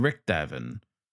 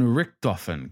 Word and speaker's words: Richthofen: Some baron, apparently, name of Manfred von Richthofen. – Von Richthofen, Captain. Let's at least do the good baron the courtesy of getting his name right. Manfred von Richthofen.